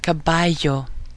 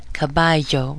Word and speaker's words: caballo: Double L, the Eye, but that's now just considered to be two L's put together, and the pronunciation sounds like a strong Y in English. So the word caballo, caballo, which means horse, it's spelled with two L's, so that Y you hear in the middle is caballo.